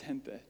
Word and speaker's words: gente: And Jessica looked at the schedule and she goes, "What is gente?" And I thought, "It's gente, Jessica." And so if you see this online, you know now how to pronounce it, gente.